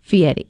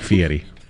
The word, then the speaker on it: Fieri: Fieri.